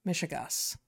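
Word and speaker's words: mishegas: She won't discuss the trans Michigas. That's how I pronounce it, right? – Yeah, that is how you pronounce it.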